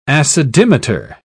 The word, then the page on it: acidimeter: British English